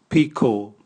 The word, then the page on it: biko: Raga